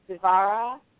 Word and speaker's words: Dvara: I'm going to spell that, but the way it's pronounced is Dvara. It's D as in door, V as in Victor, A-R-A, Dvara.